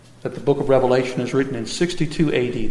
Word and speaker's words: Revelation: When you pronounce the book of Revelation, you should not put an S on the Revelation. You shouldn't pronounce it Revelations.